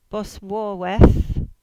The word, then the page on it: Boswarva: Cornish